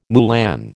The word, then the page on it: moulin: American English